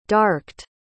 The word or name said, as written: darked